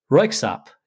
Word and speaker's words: Røyksopp: but I did a little research into them so I could know how to pronounce their name correctly and everything for this. And I realized they were Norwegian, but it's Røyksopp is the name of this band.